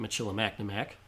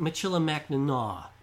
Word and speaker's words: Michilimackinac: Now, I'd like to point out, thanks to one of our listeners, it is not pronounced Fort Michilimackinac, as it's written. – That's too think. bad because that, that kind of rolls off the tongue nicely. – It does. – But it's Fort Michilimackinac because the French just can't seem to pronounce anything at the end of their words.